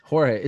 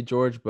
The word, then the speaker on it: Jorge: I don't know if I'm saying his name right or Jorge. It's weird. It's like his close friends say George, but I think the rest of us say Jorge.